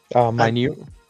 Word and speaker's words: Minot: Is it Minot? Minot? I feel like I always say this wrong. Minot State? – Minute? Yeah.